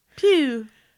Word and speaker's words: Pugh: How to say Florence Pugh? You say like that. Florence Pugh. – Oh my Pugh. – God. – Hugh. – Pugh.